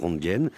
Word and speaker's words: Röntgen: Vous avez prononcé Röntgen, on va dire Röntgen, c'est comme ça qu'on le prononce en français, pour que qu'on nous comprenne.